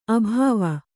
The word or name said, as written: ಅಭವ